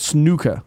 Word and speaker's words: snooker: It's actually pronounced snooker. – Snooker. – Snooker. – No, snooker. – Americans like, Americans like to say game, right? Snooker. Snooker. – Snooker. – Snooker. – Like a new in there.